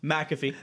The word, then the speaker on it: McAfee: McAfee.